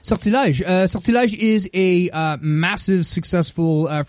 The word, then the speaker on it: Sortilège: S-O-R-T-I-L-E-G-E. – I think there's an accent. – Sortilège. Yeah. Got it. Okay. – There's an accent on the first E. I think it's sortilège. – Either sortilège. – Lège. – But I pronounce it wrong because I'm from Northern Quebec, so I say sortilège.